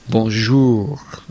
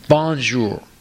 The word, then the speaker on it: bonjour: Or in there are French words in English like bonjour, hello, right? French. So bonjour. The second syllable is emphasized. But in English, you would say bonjour. You know, you emphasize the first syllable.